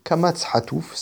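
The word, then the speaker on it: Kamatz Hatouf (קָמָץ חָטוּף): Ensuite, nous avons le Kamatz Hatouf, c'est un Kamatz qui se prononce O, comme dans Rosi.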